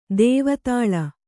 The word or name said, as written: ದೇವದಾಳಿ